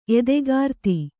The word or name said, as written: ಎದೆಗಾರ್ತಿ